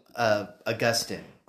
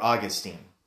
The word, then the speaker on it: Augustine: So in around the 300s AD, there was a guy named Augustine, or Augustine, depending on how you want to pronounce it.